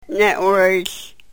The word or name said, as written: ne-wryhl